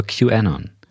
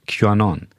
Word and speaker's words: QAnon: Ähm, folgt mir auf Social Media at Neugen Podcast und wir sehen uns nächste Woche wieder, wo wir, wo ich über Q ähnern rede ich habe das bestimmt nicht richtig ausgesprochen, aber ich rede über QAnon. QAnon.